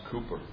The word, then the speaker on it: Cooper: spelled Cowper, but it's pronounced Cooper, served his congregation.